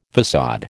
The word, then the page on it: faÇade: American English